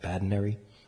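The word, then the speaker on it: Badinerie: How do you pronounce that? Badinary.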